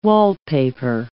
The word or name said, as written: wallpaper